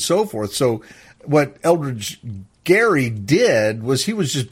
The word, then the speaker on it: Gerry: started pronouncing it, they looked at, oh, it's gerrymandering, but it was actually pronounced Gerry.